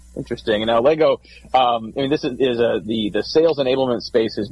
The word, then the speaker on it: Allego: You did have the name pronounced correctly. It's Allego.